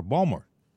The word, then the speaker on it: Baltimore: You know, everybody, and if, by the way, you're from Maryland, they pronounce Baltimore, Balmer.